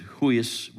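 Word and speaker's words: huis (υἱός): The Greek word is huis. It's pronounced huis, which means adult, really.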